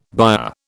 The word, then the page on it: byre: British English